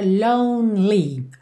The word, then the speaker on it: lonely: Atenção a essa pronúncia da palavra lonely, ok? Que esse E do meio não é pronunciado. Então, você vai do N pro L, lonely.